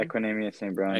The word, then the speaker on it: Equanimeous St. Brown: They're saying it might be Equanimeous St. Brown. Is that how you say his name? – Equanimeous St. Brown.